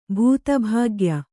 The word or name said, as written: ಭೂತಭಾಗ್ಯ